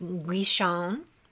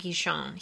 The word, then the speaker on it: Guichon: And then I think his name is pronounced Guichon. Amaury Guichon.